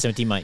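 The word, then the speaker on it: 70mai: So there's like one from 70 Mai. Is that how you pronounce the brand? – Yeah, 70 Mai.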